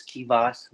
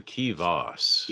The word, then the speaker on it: Keyvous: So it's more like the Voss, the Keyvous. – Yeah, Keyvous. You got to put the Voss on it. – Kind of because it rhymes with boss.